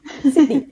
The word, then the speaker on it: Sydney: Sydney. Also Sydney. Mit dem I. Ja. Nicht so ein Singsang, sondern es ist ein I. Sydney. – Ja, mehr ein I. Mehr die Betonung auf einem längeren I.